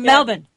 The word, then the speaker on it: Melbourne: She's from Melbourne. Yeah, you have to say it like that. – Melbourne. – Melbourne. Like New Orleans.